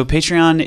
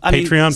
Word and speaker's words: Patreon: Patreon? Some folks, if you're from, you know, the UK or Canada, a lot of folks say Patreon.